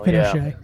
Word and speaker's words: Pinchot: Pinchot. It's pronounced Pinochet.